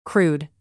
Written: crude

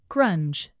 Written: grunge

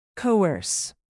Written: coerce

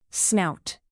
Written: snout

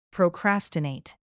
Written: procrastinate

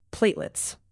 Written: platelets

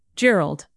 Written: gerald

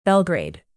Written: belgrade

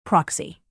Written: proxy

